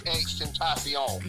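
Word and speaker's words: XXXTentacion: Not Tekashi, my bad. Extentacion. – Correct. Shit, you know how to say his name better than I do.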